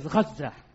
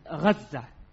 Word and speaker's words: Gaza: Who was the first, or who established the city or this habitation of Gaza, which is pronounced actually as Gaza? The correct pronunciation is Gaza.